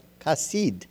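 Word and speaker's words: chesed (חסד): Okay, spelled C-H-E-C-E-D, but pronounced chesed.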